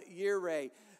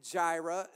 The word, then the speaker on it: Jireh: And then he provides, he is Jehovah, or Jehovah Yireh. The J is silent. If you pronounce it Jehovah and Jireh, then you're not condemned, and you're not like ungodly.